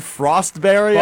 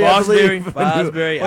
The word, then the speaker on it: Fosbury: Yeah, you said Frostberry. – Fosbury.